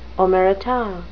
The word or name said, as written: omerta